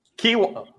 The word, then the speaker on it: quinoa: Like, trying new things as far as, I always say this wrong. Is it canola? Quinoa? How do you say it? – Quinoa. – Ooh, snap. I really messed that one up.